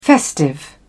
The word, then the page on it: festive: British English